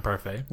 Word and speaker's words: parfait: This tea is silent. – Okay. Yeah. Yep, I know. Yeah. I know the tea is silent in parfait.